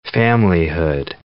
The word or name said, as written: familyhood